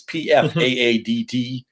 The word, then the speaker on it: Pfaadt: They're bringing him up early, and you know, half the battle with Pfaadt is just pronouncing his name right. It's P F A A D T. The P F A A D T. I don't know how Pfaadt comes out of that, But I'm going to be watching him from every pitch.